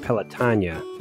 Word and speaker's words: Pelotonia: And so the people who worked there, they actually pronounce it Pelotonia. Mm-hmm. And I've just never been able to do that.